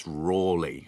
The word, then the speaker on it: Raleigh: Many of us sort of think his name is Raleigh, pronounced, spelt like the bicycle brand. But in fact, he spelt his name in all sorts of different ways, but it's often spelt in his manuscript letters, R-A-W-L-E-Y. So it's pronounced Raleigh. And I think the standard spelling nowadays is R-A-L-E-G-H.